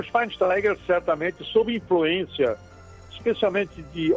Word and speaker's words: Schweinsteiger: Schweinsteiger, Bastian Schweinsteiger, abreviação hum.